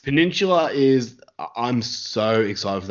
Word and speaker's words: Peninsula: Am I pronouncing it wrong? – You're probably pronouncing it fine. – Yeah, I'll say Peninsula. Yeah. – Peninsula. What am I saying? Pendula.